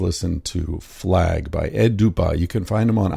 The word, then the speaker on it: Dupas: It's pronounced D-U-P-A-S, and I pronounced it Dupas or something like that last week. But he's got the fancy silent S at the end of his name, so it's Dupas.